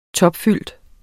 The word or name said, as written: topfyldt